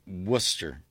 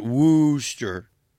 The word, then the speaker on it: Worcester: And the one that got me, if you're out in Worcester, and yeah, that's how you pronounce it, Worcester, not Wooster.